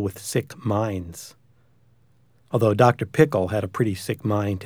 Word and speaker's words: Pickle: Actually, his name was Dr. Pickle, with the accent on the second syllable.